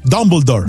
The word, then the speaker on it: Dumbledore: Dumbledore.